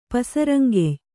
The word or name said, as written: ಪಸರಂಗೆಯ್